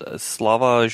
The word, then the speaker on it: Slavoj: You pronounce the J. I mean, you can pronounce it, but it's gotta be like a Y, like Slavi. – Slavoj. – Oh, Slavoj.